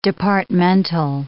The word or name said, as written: departmental